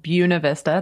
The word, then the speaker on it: Buena Vista: So we went up to a place called Buena Vista. That's how you're supposed to pronounce it.